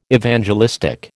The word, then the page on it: Evangelistic: American English